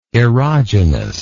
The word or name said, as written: erogeneity